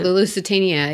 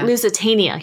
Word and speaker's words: Lusitania: How do you say it? – The Lusitania, yeah. – Lusitania, yeah.